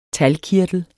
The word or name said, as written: talgkirtel